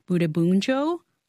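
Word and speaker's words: Bundibugyo: James, to Shashank's point about this outbreak is caused by a rare, very deadly strain of the Ebola virus called Butembunjo. Did I say that correctly? I got it.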